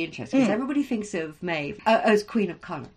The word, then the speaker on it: Maeve: Yeah, Maeve, by the way, in Old Irish, we'd pronounce her Medhav. So, okay. – But Modern Irish is Maeve.